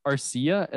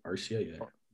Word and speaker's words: Arcia: Orlando Arcia. Is that how you pronounce it, Tyler? – Yeah. – Is it Arcia? – Yeah. – Arcia, something like that.